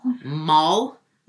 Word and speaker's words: Mohl: And then you go and marry a mole, which is actually pronounced Mall, because it's German, but everyone's like, wait, M-O-H-L. – Yeah. – But it's pronounced mole, like guacamole, mole, mole. Like, I got it all.